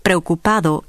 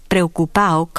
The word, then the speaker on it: preocupado: In some contexts, it totally disappears in rapid speech. This is particularly frequent in the ado endings and when D occurs at the end of a word. Thus, preocupado might be pronounced preocupado in careful speech, but it is quite likely to be pronounced preocupao conversationally.